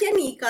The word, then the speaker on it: Yaniqua: Yes, so my YouTube channel, uh, my name is pronounced Yanika. I know it's spelled funny, but Yanika, you know, my bad.